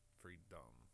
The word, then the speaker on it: Freedumb: That's spelled F-R-E-E-D-U-M-B, Norway. Freedom, like dumb.